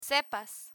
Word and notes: sepas: spanish

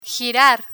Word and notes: girar: Spanish

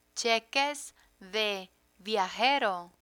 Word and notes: cheques de viajero: spanish

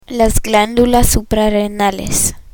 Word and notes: suprarrenales: spanish

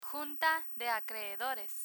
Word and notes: junta de acreedores: Spanish

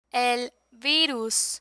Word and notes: el virus: Spanish

